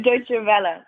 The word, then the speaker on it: Deutsche Welle: So I'm probably going to mispronounce this name, but Deutsche Welle. My German is obviously terrible. How would you pronounce that? – Deutsche Welle, but the easier version is DWTV.